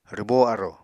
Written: rwbó arò

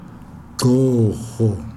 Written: gxoho

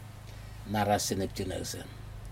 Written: nacasin neptjinasin

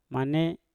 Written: mane

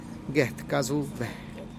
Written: get kazube

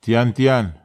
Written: tiantian

